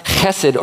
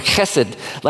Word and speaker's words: chesed: And really, it's a word that sounds like you're clearing your throat. It's pronounced chesed or chesed like that.